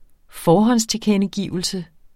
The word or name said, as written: forhåndstilkendegivelse